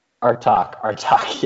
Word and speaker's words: Artsakh: I don't know how to pronounce it. Arkesh? That is the Armenian. – Is that Artsakh? Yeah. You mean? – Artsakh.